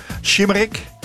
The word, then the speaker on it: Şımarık: Kiss Kiss heet het of uh, Shimmerik. Zeg ik het goed? Ja, ja, ze steekt de duim op. Gelukkig wel.